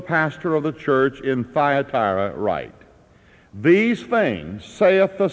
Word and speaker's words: Thyatira: It rhymes, but it is not Thyra. It is Thya, T-H-Y-A, Thyatira.